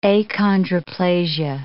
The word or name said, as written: achondroplasia